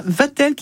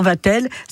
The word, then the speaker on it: Vastel: Voilà, Vatel qui s'écrit V-A-S-T-E-L. – On ne prononce pas le S, bien évidemment. – On dit toujours Vatel. Ça peut s'écrire V-A-T-E-L, V-A-T-E-L ou voilà. – V-A-S-T-E-L.